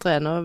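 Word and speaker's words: Renaa: Vi har fått to stjerner til Renå, eller Renault, jeg vet ikke hvordan det uttales det. Renå. ja. – ja. Eller Rænå! Han er jo trønder. Det er trøndersk, ikke fransk.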